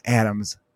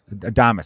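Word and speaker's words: Adames: Willie Adamas, how do you say it? Adam? It's not Adams. Adamas.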